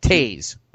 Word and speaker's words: Toews: T O E W S, Jonathan Toews. I think it's Toews.